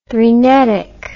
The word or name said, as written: threnetic